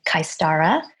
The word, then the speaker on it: Kaistara: That's my personal page, but my business page is Wendy May Kaistara, which is spelled K-A-I-S-T-A-R-A.